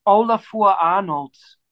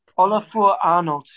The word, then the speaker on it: Ólafur Arnalds: He's called Ólafur Arnalds. – Okay. – Ólafur Arnalds.